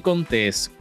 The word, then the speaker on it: Contés: Hola mi gente, mi nombre es Miguel Contés con acento en la E.